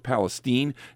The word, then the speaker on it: Palestine: And you're talking about East Palestine there, and at first I thought they were mispronouncing it, but it's actually pronounced Palestine, and I point that out for a reason.